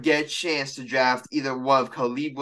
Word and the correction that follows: Caleb: It's Caleb Williams. Say his name right. Why do you keep calling him Khalib? That's racist. – Wait, what's his name called? – Caleb, not Khalib.